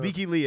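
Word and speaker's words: Lykke Li: Leaky Lee. It's spelled LYKKE space LI.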